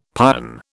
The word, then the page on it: pion: British English